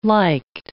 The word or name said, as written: liked